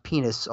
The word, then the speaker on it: PEN15: And then Breakthrough Series Short Form, you have, it's pronounced penis, although it's spelled P-E-N-1-5.